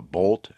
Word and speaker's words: Bolt: General Motors has an all-new, all-electric car called a Bolt, as in rhymes with Volt.